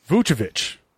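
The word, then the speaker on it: Vučević: I got a pronouncer here because I'm tired of butchering these Central European names. Vucevic. That's right.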